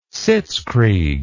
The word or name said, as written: sitzkrieg